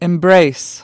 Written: embrace

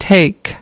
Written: take something up

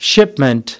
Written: shipment

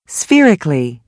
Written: spherical

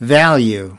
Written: value

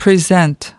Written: present

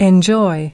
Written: enjoy

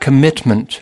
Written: commitment

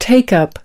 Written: take something up

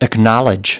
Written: acknowledge

